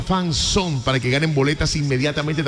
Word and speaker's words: fanzone: Entonces envía la palabra fanzone, o sea, se escribe así, fan, F-A-N, fan, Z-O-N-E, fanzone, fanzón, se pronuncia fanzón.